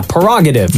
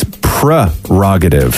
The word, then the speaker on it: prerogative: The word is spelled P-R-E-R-O-G-A-T-I-V-E. – Well, Bobby Brown doesn't say it's my prerogative. – No, he says prerogative. Prerogative. – He doesn't say prerogative. – Yeah, he does. It's prerogative.